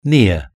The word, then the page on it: near: British English